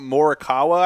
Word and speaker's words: Morikawa: Colin Morikawa, I think.